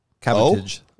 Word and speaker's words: cabotage: It's a G? – Cabutage. – Cabutage. I thought it was cabbage. – It's just you. – Cabotage. – Oh, that makes sense. It's spelled weird.